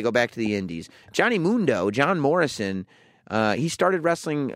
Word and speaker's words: Mundo: Taya and Johnny Mundo. That's how you pronounce it. – And now, the Sam Roberts Wrestling Podcast interview. – Well, welcome Johnny Mundo. – Mundo.